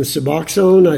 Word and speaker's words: Suboxone: I'm aware that the medication Suboxone, I think that's correct. Is that correct pronunciation? – Yes.